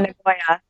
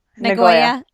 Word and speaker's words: Nagoya: Nagoya. Nagoya.